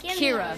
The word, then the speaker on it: Kira: You pronounced my name wrong. It's Kira. – Kira. – Like Kira Knightley.